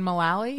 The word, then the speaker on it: Mullally: Oh my, he's married to Megan McCauley from Will and Grace. I think that's how you say her name. – Megan Mullally? – Yeah, that's it.